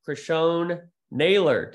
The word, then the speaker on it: Criscione-Naylor: It is my pleasure alongside the EST founder, Megan Van Petten, to be joined by Noel Criscione-Naylor. Did I say that right? – You did say it right. Thank you.